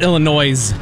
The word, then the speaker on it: Illinois: It's pronounced Illinois. – Illinois.